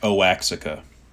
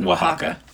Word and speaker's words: Oaxaca: I've never heard of that before, and it's the one that looks like Oaxaca. – Yeah. – It's just not pronounced that way. It's pronounced Oaxaca.